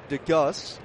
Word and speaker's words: Degeus: Anyways, they claimed DeGus is how you pronounce it. D-E-G-E-U-S.